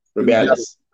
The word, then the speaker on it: Rubiales: I think it's Rubiales. – Okay. – Yeah, Rubiales.